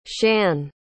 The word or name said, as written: shann